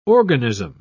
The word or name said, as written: organism